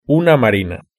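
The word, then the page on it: una marina: Spanish (Spain)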